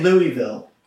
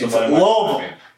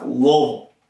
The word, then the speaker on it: Louisville: You can't say Louisville. That's wrong. – You can, but it's Louisville. It's like 1.5 syllables. It's not three. It's not really even two. It's about 1.5. Louisville.